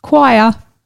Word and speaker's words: quire: The word is choir. – Choir. – Yes, I'll spell it for you. Q-U-I-R-E.